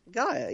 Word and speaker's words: Gaia: And I'm saying that right? – Gaia, yeah. – Because it's like Earth.